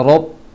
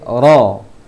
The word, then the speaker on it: Rabbi: Masih huruf fathah juga di ayat kedua surah Al-Fatihah, Rabbi, jangan Rabbi, jangan Rob tapi Ra, Rob, Rabbil, Rabbil Alamin, Ra jangan Ro.